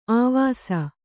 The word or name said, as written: ಅವಸೆ